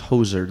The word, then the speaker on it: hoser: I'm pretty sure Hoser is H-O-S-E-R. – Well, how do you pronounce Hoser, the one you're pronouncing? – Hoser. – H-O-S-E-R? – Yeah. Like a hose with an R at the end.